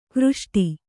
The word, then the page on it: ಘೃಷ್ಟಿ: kannada